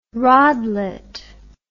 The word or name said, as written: rodlet